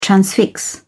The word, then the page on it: transfix: British English